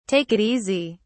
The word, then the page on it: take it easy: British English